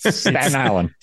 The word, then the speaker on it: Staten Island: Just like, look, Staten Island, S-T-A-T-E-N. The T-E-N at the end never happens. – Staten Island. – Staten Island. It's one word. – Staten Island.